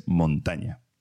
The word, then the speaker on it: Montaña: Se pronuncian, es montaña, es como dividir la montaña en dos partes, que son dos, en este caso una niña y un niño.